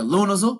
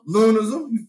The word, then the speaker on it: Lunazul: Pronounce it like a Louisville nigga. Lunazul. – Lunazul.